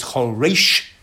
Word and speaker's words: choresh: And the accent comes on the second syllable rather than the first. There is a mercha, as those who know how to lay and call it, under the resh, which means you emphasize the second syllable of choresh, not the first. So it's choresh, and it's a tsere under the resh, and this tells you that it's a participle.